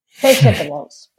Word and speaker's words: vegetables: Como por ejemplo pronunciar vegetables, que todo el mundo dice vegetables, es como vegemesa, ¿no? Es vegetables, vegetables, ¿no?